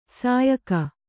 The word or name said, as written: ಸಯ್ಯುಗ